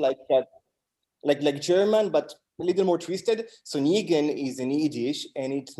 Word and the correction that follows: nigun: How do you say what Negan is? Nigun. It's like...